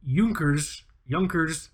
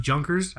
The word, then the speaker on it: Junkers: But it is the Junkers, sometimes pronounced Junkers.